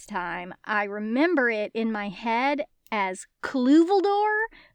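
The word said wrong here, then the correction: Cluviel Dor: so I'm glad that it's properly pronounced in the audiobook. Because in my head, I was like, where's the Cluveldor? Where's the Cluveldor? Here it is. Here's the Cluviel door.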